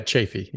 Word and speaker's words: Chaffee: Yeah, Chaffee.